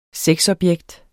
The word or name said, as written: sexobjekt